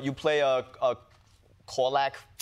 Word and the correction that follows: Korsak: Korsak fox. – Are you mispronouncing it? – Is that a real animal? – It is a real animal. – Okay. – Korsak.